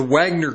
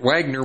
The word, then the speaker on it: Wagner: with muscle from the Wagner Group, I read it Wagner with the W, but it's actually pronounced Wagner.